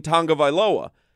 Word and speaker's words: Tagovailoa: Somebody calls in and Solana answers and the guy goes, you guys are constantly mispronouncing Tua Tagovailoa's name or whatever, you know, because there is no N in Tagovailoa. But Tua himself has done interviews. That's how it's, that's the Hawaiian pronunciation. It is pronounced Tagovailoa.